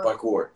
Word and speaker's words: parkour: Par- parkour.